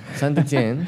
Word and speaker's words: Saint-Étienne: Saint-Étienne.